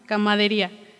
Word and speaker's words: camaradería: camadería. Camaradería. Camaradería. Hasta lo anoté así para que no lo fuera a pronunciar mal y lo pronuncié mal.